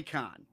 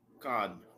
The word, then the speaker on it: Cannes: I would like to know whether it's pronounced Cannes or Con, because I've heard it both ways. – Well, it's French. So it's probably Con. – Con.